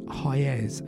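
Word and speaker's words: Hyères: I think it's pronounced Hyers, H Y E R E S.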